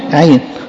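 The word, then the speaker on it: عين (עין): نطقه زي ما بقولكم في العبري عين، في تشابه كبير ما بين العبري والعربي، لغات سامية، ومعناه عين. العين بتاعت الإنسان ديت هو ده الحرف الهجاء، النطق بتاعه في اللغة العبرية عين معناها عين زي العربي كده، عين اللي هي العين.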